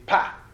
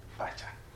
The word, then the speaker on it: Kpatsa: So the spelling is K-P-A-T-S-A, but we don't, it's when you are pronouncing it, the Ghana is going to be pa, pa, like Palogo, Pacha. But when we teach Western students, we say silence the K and say Pacha.